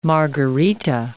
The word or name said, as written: margarita